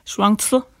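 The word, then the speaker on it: Shuangzi (双子): Nu lagde jeg mærke til, at du udtalte navnet på en anden måde end mig, og helt sikkert mere korrekt. Men forfatteren, den taiwanesiske Yang Shuangzi, sagde du det? – Shuangzi vil jeg udtale det på kinesisk.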